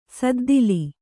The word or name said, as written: ಸದ್ದಿಲಿ